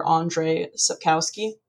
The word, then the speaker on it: Andrzej Sapkowski: Starting with The Witcher, A Grain of Truth, these graphics are going to be adaptations from the short stories by Witcher author Andrzej Sapkowski. And I did look up a pronunciation for that, so I think that's right.